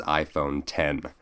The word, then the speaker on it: iPhone X: But have no fear, because Apple quickly announced the very next iPhone just moments later, dubbed the iPhone X, even though it's pronounced without the Roman numerals as iPhone 10.